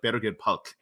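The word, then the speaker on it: Birgir Páll: Uh, his name's spelled weird, but it's pronounced like Berger Palk.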